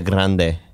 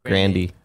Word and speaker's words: Grande: Ariana Grande. – It's Grandy. – Grandy. – The Grandy.